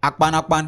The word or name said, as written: akpanakpan